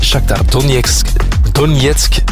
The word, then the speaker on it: Shakhtar Donetsk: En ook PSV en Shakhtar Donetsk, dat is de juiste uitspraak, die speelden 1-1 gelijk.